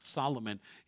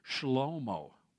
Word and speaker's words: Shlomo: It's not pronounced Solomon in Hebrew. It's pronounced Shlomo.